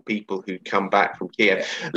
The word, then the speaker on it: Kiev: I noticed it's Kiev to rhyme with Steve, not Kiev as well.